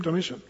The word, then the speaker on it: Domitian: Domitian.